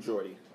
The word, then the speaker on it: Geordie: True Geordie.